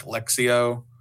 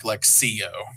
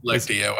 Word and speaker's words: Lectio: Yeah, it's like a sort of soft C, like S-ish, like Lectio. It's very soft, not like Lectio. – Okay. – Or just Lectio.